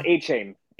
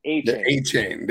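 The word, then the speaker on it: Achane: Akinane? What's his name? Akinane? How do you say his name? In my Devon A-Chain. – A-Chain. There you go.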